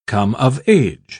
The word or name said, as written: come of age